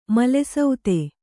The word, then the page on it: ಮಲಸುತ್ತು: Kannada; mala suttu